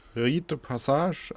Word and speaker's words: rite de passage: Rite the Passage heißt der. Wahrscheinlich muss man es eher französisch aussprechen. Wie spricht man es französisch aus? – Rite de Passage, also ja.